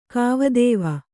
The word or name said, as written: ಕವಿತ್ವ